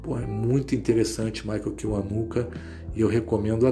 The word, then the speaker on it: Kiwanuka: Vocês podem procurar qualquer coisa do Michael Kiwanuka, é K-I-W-A-N-U-K-A, Michael Kiwanuka, quase como se pronuncia mesmo, que acho que vocês não vão perder tempo é, vendo, não.